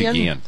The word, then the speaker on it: Guillen: In a Time magazine story, he said, Ozzie Guillen, how do you pronounce that? – Ozzie Guillen. Yeah.